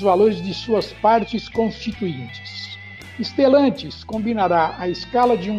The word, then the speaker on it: Stellantis: que significa iluminar com estrelas o nome é escrito com S mudo e o resto como se pronuncia, Stellantis, porém com dois L's.